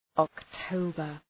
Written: October

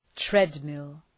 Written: treadmill

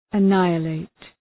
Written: annihilate

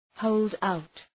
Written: hold out

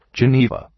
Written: Geneva

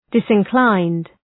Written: disinclined

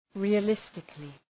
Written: realistically

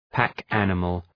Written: pack animal

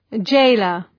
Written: gaoler

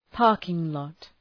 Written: parking lot

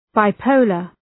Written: bipolar

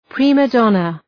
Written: prima donna

